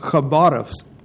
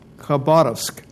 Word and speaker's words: Khabarovsk: And the KH, the beginning of Khabarovsk, is a H sound, and the stress is on the middle syllable, so it ends up Khabarovsk, Khabarovsk.